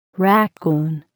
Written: raccoon

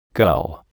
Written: gull

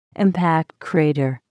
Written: impact crater

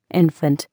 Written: infant

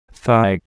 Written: thigh

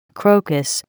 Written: crocus

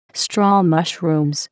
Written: straw mushrooms

